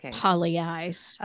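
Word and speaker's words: Pagliai's: It's one of these Italian spellings. Like, like P-A-G-L-I. It's pronounced Polly eyes.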